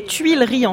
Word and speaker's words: Tuileries: Je sais que c'est difficile à prononcer, on dit Tuileries en français.